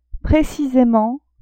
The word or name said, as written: precisement